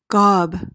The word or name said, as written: gob